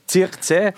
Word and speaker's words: Zirkzee: Zirkzee.